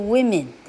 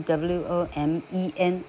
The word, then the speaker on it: women: man 的复数是 man，woman 的复数是 women，念作 women，但是是拼 w o m e n women。